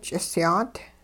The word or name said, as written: ch'isyaat